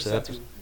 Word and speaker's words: Persephone: Her mother, Demeter, searches for her and stops all food from growing until Persephone, or Persephone, whatever, however you want to pronounce it. I'm bad with these names. – Persephone. – Persephone? Thank you.